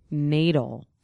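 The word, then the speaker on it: knaidel: K-K-N-A-I-D-E-L. And it means a small mass of leveled... – Silent K. Who ever heard of a silent K? – Small mass of leveled dough.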